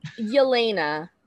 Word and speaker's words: Yelena: Yelena? – Yeah, I've really been trying to figure that out. – Yelena is how you say it.